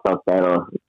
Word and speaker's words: Sotero: Sotero. But some people call it Sotero because that's like a Mexican name.